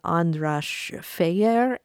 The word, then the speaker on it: András Fejér: You mentioned András Fejér. Is that how I would say his name? – Yeah, perfect.